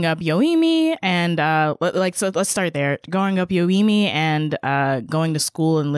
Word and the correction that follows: Yoeme: So growing up, and it's pronounced Yoeme.